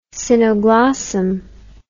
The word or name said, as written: cynoglossum